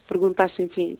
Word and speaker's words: Pergunta Sem Fim: So, speaking of jazz and, you know, speaking to your audience, really, your song, Pergunta Sem Fim, am I pronouncing that right? – Yes. Pergunta Sem Fim, yes.